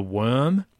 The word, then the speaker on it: wyrm: The the, the best, coolest, weirdest place that I discovered was um, the worm, um, which is spelled Y R uh, W Y R M,